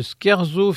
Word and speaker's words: scherzo: Le deuxième terme est scherzo, S-C-H-E-R-Z-O. Ce nom masculin se prononce scherzo, il vient d'un mot italien signifiant badinage.